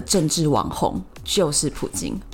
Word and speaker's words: 普京: 嗯、那我之所以习惯说普京呢，是因为用俄文发音，它叫普京，我觉得这个发音才是贴切他的真实。